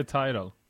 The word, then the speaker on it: Tidal: Det heter Tidal. – Jag tror det, jag såg något klipp där de uttryckte det så. Eller uttalade det så.